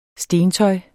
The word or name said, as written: stentøj